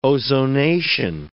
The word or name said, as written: ozonation